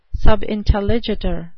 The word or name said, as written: subintelligitur